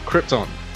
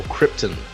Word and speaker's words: Krypton: Hello, and welcome to Last Sons of Krypton, or Krypton, as some people pronounce it, a Superman podcast.